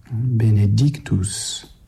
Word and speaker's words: Benedictus: Donc ici, il ne faut pas trop souligner cette deuxième syllabe, et puis il faut réserver davantage l'intensité sur l'accent, Benedictus, c'est-à-dire la troisième syllabe du mot.